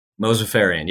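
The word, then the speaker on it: Mozaffarian: I always butcher his name, Dean Mosafarian. Is that how you say his name? – Mosafarian, yep.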